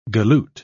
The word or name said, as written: galoot